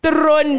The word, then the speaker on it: Theron: Theron.